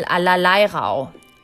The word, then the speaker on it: Allerleirauh: Allerleirauh. And Allerleirauh, it's spelled A L L E R E A L L E R L E I R A U H.